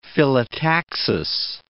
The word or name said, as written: phyllotaxis